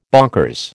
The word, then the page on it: bonkers: American English